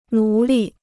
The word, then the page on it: 奴隶: nú lì